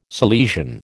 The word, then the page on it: Salesian: American English